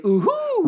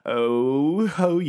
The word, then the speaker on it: Ooho: Oh, when I saw the way it was spelled, it's spelled O-O-H-O exclamation point. – I would say ooh-hoo. – Right. That's what I was saying as I was reading the articles. Yeah. I would say like ooh-ho. – Yeah. – But apparently it's called Ooho.